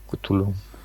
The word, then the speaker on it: Cthulhu: Cthulhu, ja.